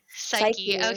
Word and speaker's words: Psyche: Psyche and the, is that how you pronounce it? – Psyche. – I thought so.